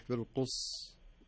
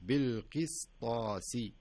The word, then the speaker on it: القسطاس: فتصبح بالقص قص والصحيح بالقس تنطق السين بحيث انك يعني يسمونها اتمام الحركات او اتمام الحروف بصفاتها ومخارجها بالقسطاسي ما اقول بالقص لا بالقسطاسي وكذلك المستقيم يعني حتى يمكن في الصلوات نسمعها بحكم انها في سوره الفاتحه نسمع بعض الناس يقول مستقيم والصحيح المستقيم.